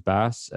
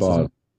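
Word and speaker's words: Bas: which features bass, or bass, I should say. Is it pronounced? – I've never. Boss. It's actually Boss. That's what he says, Boss.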